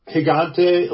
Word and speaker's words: Gigante: Gigante, it's like a Gigante, like Spanish for gigantic.